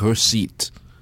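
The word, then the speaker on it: récit: Like it, Maurice Kunde, the Haitian writer, writes certain books that she calls the French is, I think, recit. Of course, being French, I wouldn't pronounce the T. R-E-C-I-T. So it's not a novel.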